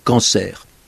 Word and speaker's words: cancer: Et on voit bien qu'il a un côté un peu hors norme, avec ce R final qui se prononce. On n'y prend même plus garde, parce que le mot est très usuel, mais il ne correspond pas aux règles ordinaires de la prononciation française. On devrait dire cancer, et on sait bien qu'on dit cancer.